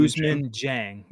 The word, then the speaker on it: Ousmane Dieng: Ousmane Dieng, right? Is that how you pronounce it? I I struggle with that name. Yeah, Ousmane, Ousmane Dieng. – Yep.